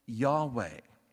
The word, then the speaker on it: Yahweh: From his name, we get the name that was probably pronounced something like Yahweh, that in our English Bibles is translated as Lord in all caps.